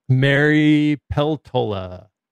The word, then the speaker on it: Mary Peltola: Mary Peltola. – Yeah. – Is that how we're pronouncing that? – Yeah, this is when Hune was on last week.